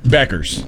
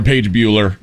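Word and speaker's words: Paige Bueckers: How do you say Paige Buchers? – Beckers. – Beckers. – Yeah. – Bueller. – Rob called her Paige Bueller.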